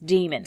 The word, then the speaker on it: daemon: Is it pronounced daemon or demon? – I don't know. – Because in the movie... – I haven't seen the movie. – The movie, it's pronounced demon. – Demon, okay. Well, it's spelled D-A-E.